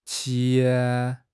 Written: qi2

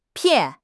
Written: pian4

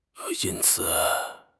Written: yin1 ci3

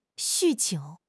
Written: 酗酒

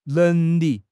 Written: neng2 li4

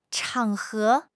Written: 场合

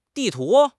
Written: di4 tu2

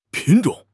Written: 品种

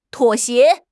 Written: 妥协

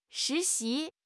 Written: shi2 xi2

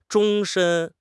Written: 终身

